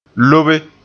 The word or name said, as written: lobe